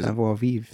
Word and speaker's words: savoir vivre: I think I fucked up saying that last time too, because I don't know how to say it. Savoir vivre? – Savoir vivre. – Is that how you say it? – That's it.